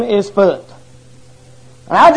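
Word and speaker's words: Phut: Now, I didn't know if that was Put or Pahut or Foot or Foot. And I looked it up in my Strong's Concordance, and it's pronounced Foot. F-U-T.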